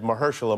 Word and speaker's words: Mahershala: I honestly, when I was walking down the stairs, I said Mahershala, Mahershala, Mahershala, Mahershala.